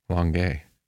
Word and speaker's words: Longueuil: Longueuil.